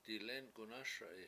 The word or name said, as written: dilEnk7unASa7i